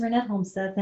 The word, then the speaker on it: Ranette Holmseth: So my name is Ranette Holmseth, and I live in Minnesota.